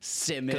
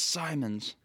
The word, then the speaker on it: Simons: Remember how pissed he'd get when people would call him Simmons? – Simmons. – Because it was spelled like Simmons, and he's like, "It's Simons."